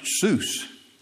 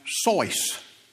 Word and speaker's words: Seuss: Except It's not really pronounced Seuss. It's correctly pronounced Sois.